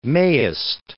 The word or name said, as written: mayest